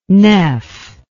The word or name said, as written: nef